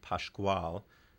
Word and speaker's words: Pascoal: Hermeto, H-E-R-M-E-T-O, H-E-R-M-E-T-O, Pasquale. I'm copying Henry's pronunciation a little bit. P-A-S-C-O-A-L, P-A-S-C-O-A-L, Hermeto Pasquale and a Grupo is the name of the ensemble that plays with him.